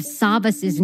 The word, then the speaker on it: Savvas: I was informed by a family friend and a family member that it's not Savopoulos, that it's actually pronounced Savopoulos, and that the S at the end of Savas's name is actually silent. It's Sava.